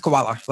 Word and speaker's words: Kawula: But my last name's Koala, like the bear. And it's pronounced that way.